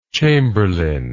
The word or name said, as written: chamberlainship